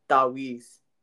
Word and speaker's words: Taweez: But they're called, you want to pronounce it correct for me? – Yeah, it's called Taweez.